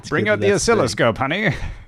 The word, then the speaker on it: oscilloscope: oscilloscope. Did I say that right? – Oscilloscope.